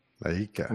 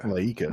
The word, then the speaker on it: Laika: It sounded like Laika. – Laika. – Laika.